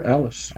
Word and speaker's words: Allis: Yes, sir, Allis.